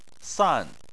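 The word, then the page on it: san: tone 4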